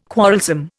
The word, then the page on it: quarrelsome: Indian